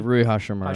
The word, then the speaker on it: Hachimura: I don't think, look, Jokic was much better in the first half, and then the Lakers made that adjustment of putting, how do you pronounce his name? Rui Hachimura on him. – Yeah, Rui Hachimura. – Hachimura uh-huh.